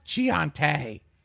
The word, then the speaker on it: Chianti: It's pronounced Chianti. – Yeah. Chianti.